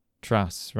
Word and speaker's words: Truss: She wasn't actually Prime Minister long enough for me to find out how to correctly pronounce the name. How is it? – Truss, right, okay.